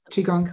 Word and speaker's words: Qigong: I hope I'm pronouncing it properly. Qigong, I know Qigong. – Correct.